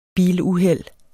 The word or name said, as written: biluheld